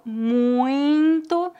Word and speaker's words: muito: Muito tem uma pronúncia nasal, muito, mas não devemos escrever desta maneira. A forma correta de escrever é M-U-I-T-O, apesar de ter uma pronúncia nasal.